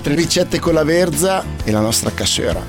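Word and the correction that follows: cassoeula: No, non si dice cassuera, lo dici solo tu, si dice cassuela, sei l'unico che lo dice con la R.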